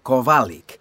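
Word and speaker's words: Kowalik: Jan Kovalik. – Can you spell that, please? – Yes. My first name is Jan. That's J-A-N. – Okay. And can you spell your surname, please? – Yes, it's Kovalik. That's K-O-W-A-L-I-K.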